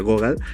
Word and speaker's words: Gogol: Se escribe Gogol, pero se pronuncia Gogol, eh, por si lo han visto por allí.